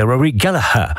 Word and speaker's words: Rory Gallagher: Rory Gallagher, encore que Rory Gallagher, si vous le prononcez comme ça, on va vous regarder de travers, puisque pour les Irlandais, c'est Rory Gallagher.